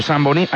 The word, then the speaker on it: Zamboní: ¿Zamboní es como se pronuncia? – Sí, señor, Zamboní.